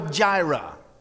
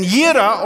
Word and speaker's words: Jireh: And Yira or Jireh, whichever way you want to pronounce it, literally means the God who provides.